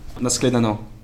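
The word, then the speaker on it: Na shledanou: Naschledano.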